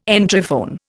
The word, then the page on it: Entryphone: Indian